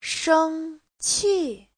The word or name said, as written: sheng1qi4